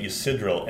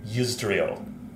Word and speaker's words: Yusdrayl: I think I was pronouncing it Ysidril, and it's Yusdrayl. I like flipped a couple, um, had a reversal of a couple of the syllables.